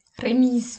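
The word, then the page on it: remise: Bavarian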